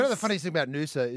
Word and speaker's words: Noosa: Noosa.